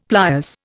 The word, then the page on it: pliers: Indian